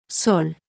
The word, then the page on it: s: Spanish